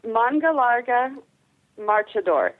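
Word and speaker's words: Mangalarga Marchador: Okay, Manga Larga Marchador, and it's spelled just as it sounds. – Mungalaga Marchador, and is it?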